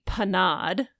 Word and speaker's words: panade: a tre dans la panda which um should be panade mm-hmm. not panda